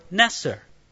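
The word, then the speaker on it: netser: The Hebrew word translated branch in Isaiah 11, 1 sounds like the word Nazir. It's the word Neser.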